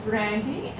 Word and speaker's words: Randy: My name is Randy, and it's spelled R-A-N-D-Y. So when you're writing it across the line, it's with a Y.